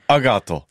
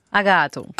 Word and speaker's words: Agato: Comment prononce-t-on Agathe en occident Justement, c'est lundi, donc on va faire très très simple, l'ISS. Merci. Agato. – Agato.